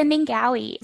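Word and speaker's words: Ningaui: I saw a word that I'd never heard before, the Mallee, I don't know how to say it, Ningwai? – So it's a Ningawi.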